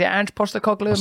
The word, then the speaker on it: Postecoglou: Is it Ange Postecoglou? – Am I saying it right? Yeah.